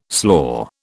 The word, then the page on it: slaw: British English